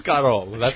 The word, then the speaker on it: escarole: And there's a goddamn pizzeria that makes the most delicious soup with beans and this leafy, spinachy vegetable that is spelled E-S-C-A-R-O-L-E.